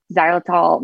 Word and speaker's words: xylitol: Um, in case like if someone wants to go- Google it, it's with an X. It's like X Y xylitol.